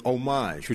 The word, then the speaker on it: Homage: The great people at Homage, which I'm reliably informed that's how you say it over here.